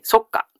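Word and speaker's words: 足下: 足の下、足の下と書いて何と読みますか？はい、そっかと読みますね。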